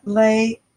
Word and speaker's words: lei: L-E-I. – L-E-I. Mm-hmm. And how do you say it again? – Lei. – Lei. Oh, like a Hawaiian lei.